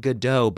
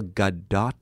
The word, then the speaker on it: Gadot: Gail, not Godot, but Gail Gadot.